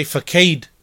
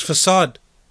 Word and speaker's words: facade: He may say facade, reading the word facade, but the word is facade.